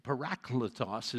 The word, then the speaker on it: parakletos: Well, that's what the paraclete, the parakletos is how it's pronounced in the Greek.